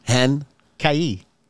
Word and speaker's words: Henkie: H-E-N-K-I-E. Hen? – Hen? – Kai. – Kai. That's right. – Hen Kai.